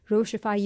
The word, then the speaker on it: Rochie: रोशिफाई you as well.